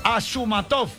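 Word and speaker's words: Ashurmatov: Luego viene Asmu. Epa, ahí me equivoqué ya arrancando. Es Ashumatov. Ashumatov. Luego viene Abdulayev.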